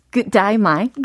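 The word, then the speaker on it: G'day mate: And if you want to do it in the real Australian way, it mm-hmm. is good day mate. 그다이마이.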